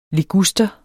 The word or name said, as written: liguster